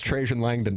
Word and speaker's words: Trajan Langdon: Tim, Auburn, it's pronounced Trajan Langdon.